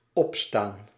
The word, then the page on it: opstaan: /'ɔp.stan/